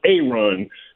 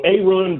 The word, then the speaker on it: Aaron: It's spelled Aaron. It's pronounced A-Run. So A-Run Brewer talked about he's a headhunter out there.